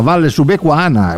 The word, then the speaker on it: Valle Subequana: Gra- gra- grazie a te, eh, ho detto giusto, no? Valle Subequana. – Tutto eh, perfetto.